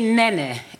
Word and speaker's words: Neneh: It's Nena Cherry. it's actually Nene. N-E-N-E-H. – Nene. – Nene. – Okay. – Nene. – Will I say it again and we can do it? – Two syllables, come on now.